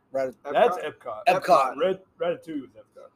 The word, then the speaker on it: Ratatouille: Ratatouille. – Whatever. – You don't pronounce the L. – The double L is not pronounced in French.